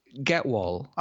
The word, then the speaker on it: Gehwol: So, Getwall, pronounce that? – Getwall. – Okay, right. Yeah. Sounds like there's a T in there, but there isn't a T in there. – No, there's no T.